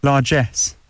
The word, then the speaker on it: largesse: I better get the pronunciation right. – Largesse. – Yeah, well, I was close.